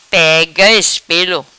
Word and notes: Pega Ispelo: Patuá

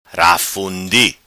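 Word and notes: Rafundi: patua

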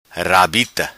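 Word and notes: Rabita: patua

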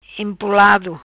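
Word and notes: Empolado: Patua

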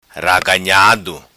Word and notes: Raganhado: patua

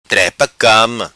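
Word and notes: Trepa Cama: Macanese Patuá

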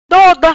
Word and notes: Doda: Patua

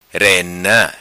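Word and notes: Rena: patua

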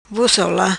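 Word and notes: Ola Vos: patua

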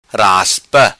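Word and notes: Raspa: patua

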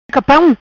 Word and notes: Capam: patua

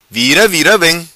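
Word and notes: Vira Vira Vem: patua

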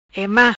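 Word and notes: Ema: Patua

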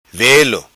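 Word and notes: Velo: patua

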